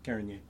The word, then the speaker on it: Carignan: Carignan.